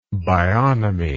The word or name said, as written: bionomy